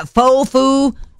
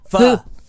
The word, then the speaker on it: pho: Fofu? – Pho.